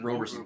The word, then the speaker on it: Roberson: They were just Roberson or Robertson? – Roberson.